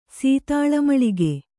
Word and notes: ಸೀತಾಳಮಳಿಗೆ: sītāḷa maḷige

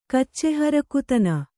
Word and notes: ಕಚ್ಚೆಹರಕುತನ: Kannada

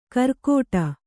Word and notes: ಕರ್ಕೋಟ: karkōṭa

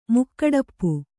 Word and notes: ಮುಕ್ಕಡಪ್ಪು: Kannada

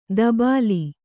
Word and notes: ದಬಾಲಿ: dabāli

